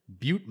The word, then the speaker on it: Butte: I really did not know that it was pronounced Butte, Montana.